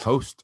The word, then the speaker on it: Jost: J is silent. Jost. – Yes. Maybe it's host. – Host. – Host with the most.